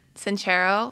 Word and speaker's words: Sincero: And then a lot of people were telling me about the, I'm going to say her name wrong, Jen Sincero. – Oh, Sincero.